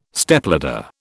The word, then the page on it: Stepladder: British English